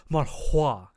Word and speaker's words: manhua: Uh, I honestly I don't have much to say about this one aside from it's another manhwa adaptation and eh, which... Mon- no, you got to say it right. It's manhua. Manhua.